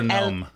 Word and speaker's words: Noem: I call her Noem.